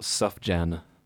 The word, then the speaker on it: Sufjan: Sufjan Stevens, spelt Sufjan. – Yeah. – Sufjan Stevens. Yeah, like I think I'm pretty sure that's how you pronounce it. – S-U-F. Interesting.